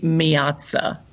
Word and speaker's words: Miazza: The truth is, it should be Miazza. That is correct. Miazza. Miazza. Yeah. But the Southern drawl has destroyed it and turned it into Miazza.